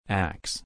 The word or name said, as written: axe